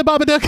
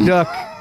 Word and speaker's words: Babadook: Did you guys see the Babadook? – Yeah, I know. – It has to rhyme with book.